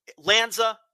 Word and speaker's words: Lanza: But Lanza, like Stanza, Nissan Stanza.